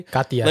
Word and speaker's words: Katya: Her name is Katya, so it's K-A-T-Y-A. I'm sorry if you don't know how to pronounce that. Katya, maybe? – Katya.